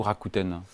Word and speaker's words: Rakuten: Euh, petite question, ce que on dit euh, de prononciation, on dit Rakuten ou Rakuten on dit euh, Rakuten, même si euh, le mot est japonais.